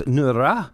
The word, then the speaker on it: Nora: But I asked the Norwegians that day of something about, do you know how it got to be called Nora? And they corrected me that I wasn't pronouncing it right. It's Nura. So you got to roll your R's.